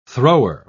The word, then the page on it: thrower: British English